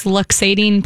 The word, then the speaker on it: luxating: Okay, she has a question about a dog's luxating patella. – Yeah, I said that wrong, too. I said lactating. – Oh, yeah, no, luxating.